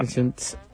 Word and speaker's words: Junts: Como lo indica bien su falta de vocal en la última sílaba. Junts.